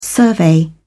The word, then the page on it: survey: British English